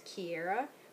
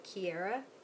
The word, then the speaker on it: Kiera: So my name is spelled K-I-E-R-A, not like your typical Kiera, which is K-E-I-R-A or K-I-R-A. It's K-I-E-R-A. So my name is actually supposed to be pronounced Kiera, which is like key, like lock and key, air, you know, oxygen, air, and then so Kiera.